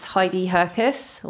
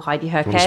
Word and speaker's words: Heidi Herkes: So it's Heidi Herkes or Heidi Herkes. – Spell that. So it's Heidi, H-E-I-D-I, and Herkes, H-E-R-K-E-S.